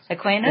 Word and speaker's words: Aquinas: Aquinas? – Aquinas.